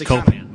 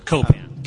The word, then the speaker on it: Copan: That's Copan, by the way. – Copan? – Accent on the first syllable. Copan.